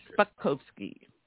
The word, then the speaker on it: Spakovsky: It's just Spakovsky. Yes.